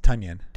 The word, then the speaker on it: Tonyan: Kittle, Jonnu Smith, Mark Andrews, and then Robert Tonyan. – It's Tonyan.